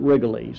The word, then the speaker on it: Rigolets: It's spelled Rigolets, but the people of New Orleans pronounce it Rigolets, so we'll honor their wishes tonight.